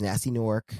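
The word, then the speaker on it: Gnasty Gnorc: Did you pronounce the G in the first one? Nasty? – Nasty Nork. – Oh, okay, yeah. I thought you still had the G and they're like, nasty. – Nasty. – Like gnocchi.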